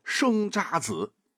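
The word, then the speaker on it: 生查子: 而除了踏缩行，那还有很多词牌这个名啊也很美，比方说生渣子。这个渣就是茶案的那个茶，在这里啊要读作渣。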